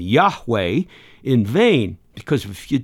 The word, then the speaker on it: Yahweh: So those four consonants in Hebrew, nobody knows how to pronounce it. About 200 years ago or so, German theologians decided to add their vowels, and they came up with, guess which word? Jehovah. Well, that was a German attempt. And the Jehovah is often included in a lot of our Bibles. Today, most scholars believe that those four letters would be pronounced, here you go, Yahweh.